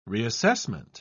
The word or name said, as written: reassessment